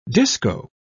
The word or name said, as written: disco